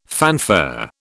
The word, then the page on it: fanfare: British English